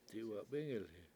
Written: diwatbIngilhi7

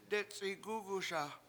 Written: detc7igUguSa

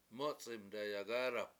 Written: mUc71m dayagAAdap

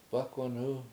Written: bAkwanu

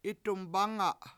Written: 7it7umbAnga7

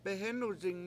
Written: behEnuzing

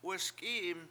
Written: wesk7I7im

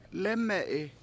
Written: lEme7i

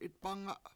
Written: 7itbAnga

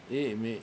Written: I7imi

